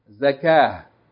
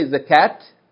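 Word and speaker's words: Zakah (زكاة): That's ta mabuta on the end. But we don't pronounce the T. We don't say Zakat. Huh? We don't say Salat. Huh? Alaykum As-salam wa rahmatullah. Okay? We don't say Zakat. We don't say Salat. Because that ta marbuta, if you stop on it, you don't pronounce like T. You pronounce like Ha.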